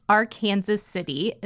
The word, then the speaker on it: Arkansas: Specifically, Arkansas City is a small city southeast of Wichita near the Oklahoma border. – So you'll notice that it's pronounced slightly different than most people would think.